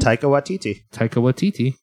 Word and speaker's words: Taika Waititi: That's the new movie from, how do you say his name? – Taika Waititi. Yep.